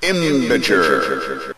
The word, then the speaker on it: IMBJR: The Caribou and the Beaver by Imbiger. That's how you pronounce IMBJR, by the way.